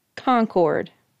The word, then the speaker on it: Concord: County seat is Concord. Not Concord. Concord.